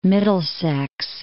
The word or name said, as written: Middlesex